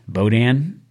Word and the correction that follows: boudin: Boudin. – Boudin.